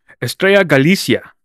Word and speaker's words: Estrella Galicia: Estrella Galicia.